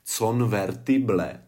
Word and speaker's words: convertible: Convertible, tak je tam O, E, I, E. A všechny ty slabiky se vyslovují jako E.